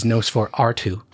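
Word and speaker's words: NOS4R2: One funny thing about the Nos 482, the UK version of it is Nos 4R2. Because when you pronounce it out loud, it's still Nosferatu, right? – Yeah.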